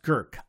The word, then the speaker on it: Grk: It's spelled G-R-K. I kid you not. But it's pronounced Gurk, I believe.